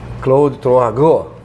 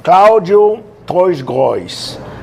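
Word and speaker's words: Claude Troisgros: Temos a honra de receber aqui Claude Troagô.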